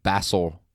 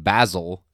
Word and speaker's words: Basil: Like B-A-S-S-I-L. Yeah. – And that became like an inside joke between me and Brooke. My dad continues to call him Basil with an S when in England, the name Basil. – Mm-hmm. It's pronounced like Z. It's pronounced like a Z sound.